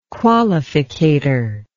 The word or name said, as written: qualificator